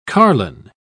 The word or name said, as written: carline